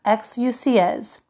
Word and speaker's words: exousias: It's spelled E-X-O-U-S-I-A, but pronounced exousias.